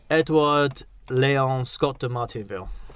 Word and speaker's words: Edouard Léon Scott de Martinville: This guy, I'm pronouncing his name like this, Edouard Léon Scott de Martinville. – That's probably as good an approximation as any, I think.